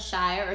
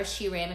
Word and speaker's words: Shire: And I say this because she has been using the work of a poet named Warsan Shire, or Shiram if you're saying it wrong.